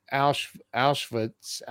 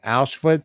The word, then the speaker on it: Auschwitz: So you were in Auschwitz. I don't even know if I'm saying it right. Auschwitz.